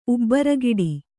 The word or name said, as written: ಉಬ್ಬರಗಿಡಿ